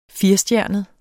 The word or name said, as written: firestjernet